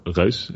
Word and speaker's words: Reus: But at the end of the project, which was May 2013, when Reyes came out, or Reus, as we pronounced in Dutch, the team actually grew to like 16 people that had worked on it.